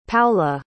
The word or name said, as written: Paola